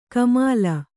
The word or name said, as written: ಕಾಮಿಲೆ